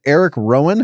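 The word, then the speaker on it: Erick Rowan: Facing off with Eric Rowan. Did I say that right? – Yeah.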